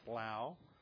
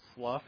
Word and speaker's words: slough: The Oxford English Dictionary prefers the pronunciation slough. Apparently that is the British form. The pronunciation slough is the verb, like a snake sloughs off its skin. But the general American pronunciation is slough.